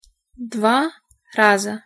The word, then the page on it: dva raza: Russian